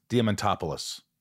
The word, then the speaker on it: Diamantopoulos: This is called Shit Talking with Chris Diamantopoulos. Did I say that right? – You said it right. – Look at that. – You got it. You said it like my mother.